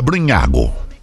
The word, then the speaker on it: Brugnago: Diego Lopes Brugnago. B-R-U-G-N-A-G. – Brugnago. – É, a pronúncia é Brugnago.